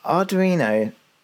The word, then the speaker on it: Arduino: Because Arduino is a brilliant project.